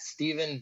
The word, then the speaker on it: Stephen: It's at Steven Vidovich, spelled like Stephen Curry, first name.